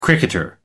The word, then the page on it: cricketer: American English